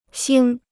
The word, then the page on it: 腥: xīng